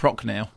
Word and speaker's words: Prochnow: Jason Isaacs, Julia Ormond, Jürgen, is it Prochnow? Would you say? – Well, listen, the last communication I had from Jason Isaacs said that he laughs every time I mispronounce something, so I'm going to say Prochnow.